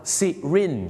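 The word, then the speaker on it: syringe: เขาไม่ได้ออกเสียงว่าไซลิงนะครับไม่งั้นเดี๋ยวฝรั่งเขาจะนึกว่าคุณจะเอาเขาขึ้นสลิงห้อยโหนในโรงพยาบาลสิครับที่จริงแล้วนะครับคำว่าเข็มฉีดยาเขาออกเสียงว่าซิรินจ์นั่นเ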